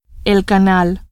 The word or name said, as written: canal